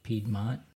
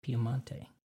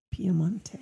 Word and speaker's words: Piemonte: Kind of Americans pronounce it Piedmont. Italians say Piemonte. – Piemonte.